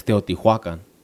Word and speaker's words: Teotihuacan: To talk about that, like, you know, I went to the pyramids in Mexico City, and I thought that you always pronounced them Teotihuacan. And when we got there, our tour guide was like, nah, it's Teotihuacan.